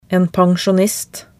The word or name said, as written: en pensjonist